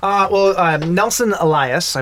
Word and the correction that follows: Nelson Ellis: Nelson Ellis. It could be Elias. – It's E-L-L-I-S. – L-I-S. Oh L-I-S.